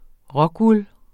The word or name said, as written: rockwool®